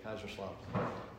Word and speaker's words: Kaiserslautern: We're going up to Kaiserslautern. Is that how you say it? – Some people call it K-Town, but I'm trying to say it the German way, Kaiserslautern.